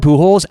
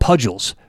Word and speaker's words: Pujols: he pronounced Albert Pujols Albert Pudgels for a lot of his sports betting career.